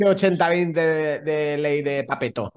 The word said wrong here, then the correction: Pareto: Oh, no sé cómo se dice. Pareto, pareto. – Pareto, vale.